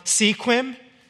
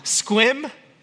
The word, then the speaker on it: Sequim: And it started in a town that I used to pronounce Sequim. Squim, as it turns out, to Port Angeles.